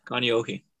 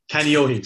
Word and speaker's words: Kaneohe: Kanohe or something? – Yeah, Kaneohe. – Kaneohe, sorry.